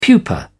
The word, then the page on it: pupa: British English